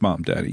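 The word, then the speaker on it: Mamdani: But this is the one thing about this mom daddy, that is how you pronounce it, isn't it? Mom Danny. It is Mom Danny.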